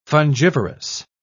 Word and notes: fungivorous: British English

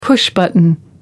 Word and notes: push button: American English

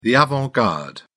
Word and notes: avant garde: British English

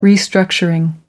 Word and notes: restructuring: American English

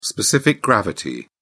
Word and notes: specific gravity: British English

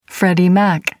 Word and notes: freddie mac: American English

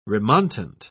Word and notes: remontant: British English

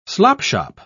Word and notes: slopshop: British English

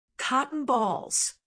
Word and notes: cotton balls: American English

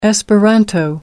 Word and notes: esperanto: American English

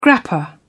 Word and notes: grappa: British English

